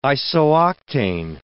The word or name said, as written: isooctane